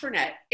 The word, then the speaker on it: Fernet: And I didn't even know if it was pronounced Fernet or Fernet. – Yeah, so it's pronounced Fernet.